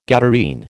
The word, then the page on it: Gadarene: American English